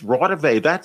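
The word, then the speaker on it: Raudive: But then I realized the next morning, wait a minute, he just mispronounced it. It's Rawdive. I know who that is, Konstantin Rawdive.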